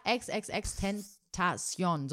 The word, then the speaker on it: XXXTentacion: Ja, XXXTentacion. So Alter, spricht man ihn aus.